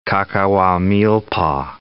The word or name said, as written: Cacahuamilpa